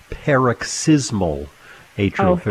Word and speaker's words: paroxysmal atrial fibrillation: I have two questions for you regarding a diagnosis of, and I hope I pronounce this properly, paroxysmal atrial fibrillation. – Okay, let's go back to med school. I think it is paroxysmal atrial fibrillation.